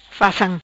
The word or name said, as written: Facam